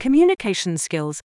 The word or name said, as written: communication skills